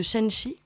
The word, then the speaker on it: Shanxi: la province de Shanxi.